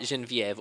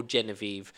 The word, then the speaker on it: Genevieve: That's right, Genevieve or Genevieve. They pronounce it both ways, I think, to like make the syllables work for whatever the lyric was at some point.